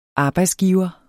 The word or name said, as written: arbejdsgiver